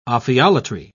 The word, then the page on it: ophiolatry: British English